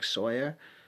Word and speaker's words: Sawyer: My name is Derek Sawyer on Facebook.